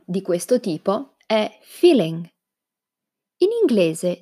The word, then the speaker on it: feeling: Naturalmente l'italiano pronuncia anche la G finale, quindi feeling.